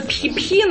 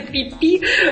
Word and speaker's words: Пхи-Пхи: Ну, вообще оно пишется пхи-пхи, но по всем правилам произношения, на самом деле это пи-пи.